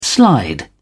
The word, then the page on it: slide: British English